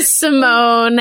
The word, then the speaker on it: Simone: I don't know why my parents put that accent there, but okay, the accent is silent. Great. Studio Simone, and how that came to be and kind of where you're hoping to go with it.